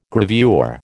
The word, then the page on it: gravure: American English